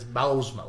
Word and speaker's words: Bousma: it is Bousma, like Hausma.